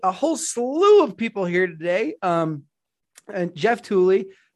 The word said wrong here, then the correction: Tolley: is it Tooley or Tolley? – Tolley. – Tolley.